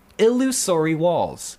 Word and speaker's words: Illusory Walls: Illusory walls. That's the correct pronunciation.